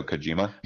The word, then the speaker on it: Kojima: Norman Reedus, and also of course the guy who pretty much put Konami on the map, Hideo Kamoji, I believe. If I'm pronouncing his name right, but Hideo Kojima. – Thank you, Hideo Kojima.